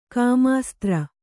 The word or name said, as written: ಕಾಮಾಸ್ತ್ರ